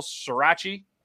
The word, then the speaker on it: sriracha: Maple sriracha. – Sriracha.